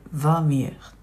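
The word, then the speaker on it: Vermeer: 語でヴーミエと言いますえー、フェルフェルメールは、まあ、オランダ語ではフェルメールなんでだと思うんですけれど英語読みになると「ブイン」で始まるので「ヴァミエル」というふうに発音します、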